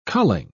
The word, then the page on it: culling: British English